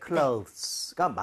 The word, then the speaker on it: clothes: clothes가 맞는데요.